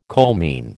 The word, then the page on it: coalmine: American English